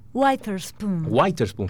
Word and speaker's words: Witherspoon: Creo que se pronuncia así. – Witherspoon, está bien.